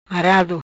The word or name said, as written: Marado